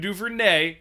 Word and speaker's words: Duvernay: What I like coming out of Baltimore, Devin Duvernay, and yes, that is how you pronounce his name, he's had 5 games of double-digit points this season.